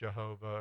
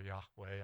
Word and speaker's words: Yahweh: And remember, if you're following along in the text, everywhere it says Lord, and it's all in caps, it's God's sacred name, pronounced Jehovah or Yahweh, and I'm using Yahweh.